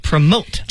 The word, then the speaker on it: promote: Promote，注意它的读音又变成了 promote。